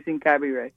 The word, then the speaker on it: Ingabire: I believe, you know, they let me pronounce it one way for a long time just because they were so glad I was reporting on it. No one corrected me, but I think that the proper pronunciation is Ingabire.